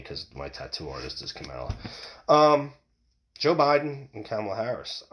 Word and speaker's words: Kamala: I'm just, I think it's Kamala Harris. It sounds Kamala. That's how it's said.